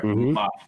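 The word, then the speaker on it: Mbappé: Mbappe. – Sorry. Mbappe.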